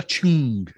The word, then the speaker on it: Chng: This is, I'm going to try and pronounce this right. It's Karla Chng, Chng. There's no vowels in the name, and it's a new track by her, and it's called Red Sea.